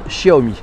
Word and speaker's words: Xiaomi: Euh, alors Chénard nous dit c'est la prononce Xiaomi. Xiaomi, ça se prononce Xiaomi.